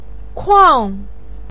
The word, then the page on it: kuang4: pinyin